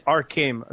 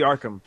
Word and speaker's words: Arkham: I just want to, you pronounce it Arkham Asylum. It's totally Arkham.